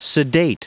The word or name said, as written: sedate